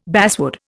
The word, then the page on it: basswood: Indian